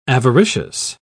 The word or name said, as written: avaricious